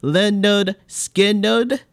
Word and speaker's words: Lynyrd Skynyrd: Their debut album, pronounced Lynyrd Skynyrd,